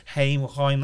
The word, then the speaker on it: Haim: So you've got that girl from the band Haim. That's how they say it, isn't it? Yeah. – Haim, that's it.